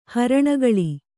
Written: ಹರಣಗೊಳ್

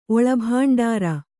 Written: ಒಳಭಾಂಡಾರ

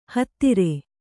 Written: ಹತ್ತರ

